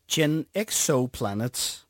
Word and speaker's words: Gen Exoplanet: Men før vi overhovedet når til at spille noget af din musik, så skal jeg også lige høre om et projekt, som jeg udtaler det sikkert forkert, Gen Exoplanet. – Ja, spot on.